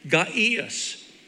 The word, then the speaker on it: Gaius: I had to look that word up, how to pronounce it in Strong's in the Greek. Gaius, okay?